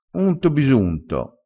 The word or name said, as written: unto